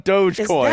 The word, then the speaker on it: Dogecoin: AMC, Dogecoin. We're all going in on Dogecoin.